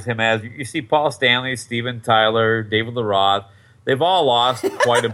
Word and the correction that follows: Lee Roth: That's not. It's David Lee Roth.